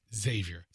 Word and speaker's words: Xavier: Yeah, anything that starts with an X is Z sound. It's Xavier.